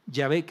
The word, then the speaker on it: Yahvé: Yahvé,